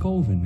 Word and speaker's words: Coven: It's pronounced Coven, man. What else could it be pronounced? – Coven. That's the proper pronunciation. – No. Coven sounds like oven, man, and that's just, it doesn't work.